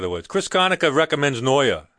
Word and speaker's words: Noyer: It's spelled Neuer, N-O-Y-E-R, probably pronounced. – Neuer. – Yeah, probably pronounced like that.